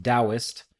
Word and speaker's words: Taoist: I also know that the word Taoist is pronounced Taoist, but for whatever reason that feels disingenuous to me saying it, it feels affected on my part.